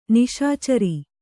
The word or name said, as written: ನಿಶಿಚರ